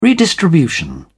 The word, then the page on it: redistribution: British English